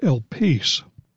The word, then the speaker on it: elpis: The word hope is a Greek word pronounced elpis, and it usually connotes expectation rather than just a mere wish or ardent desire.